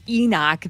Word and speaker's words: Eknock: Fawzwaz Yazzie's stage name is Enoch. That's his music. I would die for this. And no, it's not spelled E-N-O-C-H like the patriarch. I heard Kale joking about that. It's so cool. It's spelled E-K-N-O-C-K.